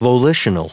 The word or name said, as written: volitional